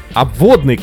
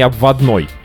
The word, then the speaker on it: Обводный: Об обводный канал, а не обводной.